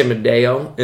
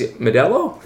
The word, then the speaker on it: Modelo: L the L is pronounced in Modelo. I try to say Medeo, and then the sort of the barman always says Modelo, like American.